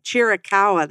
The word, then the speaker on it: Chiricahua: Tucson has an entire group, and now they have the Black Star Chikawa site. I think it's how you say it, Chikawa. But they have these locations, Chiricahua. – Chiricahua that's what it's called.